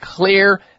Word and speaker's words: Xlear: Make sure your nasal hygiene, just like your dental hygiene and all the rest of your hygiene, is up to speed with clear nasal sprays. Clear is spelled X-L-E-A-R, and you can find clear products, including clear nasal sprays.